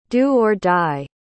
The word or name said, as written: do-or-die